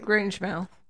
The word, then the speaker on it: Grangemouth: were bumpered in with a band called the Cocteau Twins, formed in 1979 from Grangemouth. Am I saying that right? – Grange mouth.